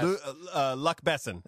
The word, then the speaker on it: Luc Besson: Luc Besson.